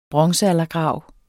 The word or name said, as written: bronzealdergrav